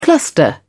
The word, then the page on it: cluster: British English